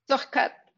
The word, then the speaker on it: Zuchkat: Zuchkat, but you can call me JT for English people. It's easier.